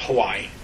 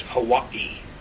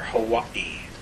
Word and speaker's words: Hawaii: It's not Hawaii. It's Hawaii. To say it properly, you've got to put a pause there. Hawaii.